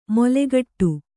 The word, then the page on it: ಮೊಲೆಗಟ್ಟು: molegaṭṭu; Kannada